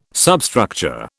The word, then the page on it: substructure: British English